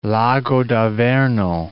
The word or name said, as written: Lago d'Averno